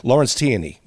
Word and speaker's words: Lawrence Tierney: Is it pronounced Lawrence Tierney? – Lawrence Tierney, yeah.